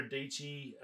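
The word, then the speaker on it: Dietschy: I did caught up with, watched a little bit of Sarah Deechi, Rhymes with Peachy.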